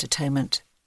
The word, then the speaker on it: atonement: We believe one final shift is called for in our thinking about atonement. Oh, that we still pronounced atonement as it would have been heard in William Tyndale's pronunciation, at-one-ment.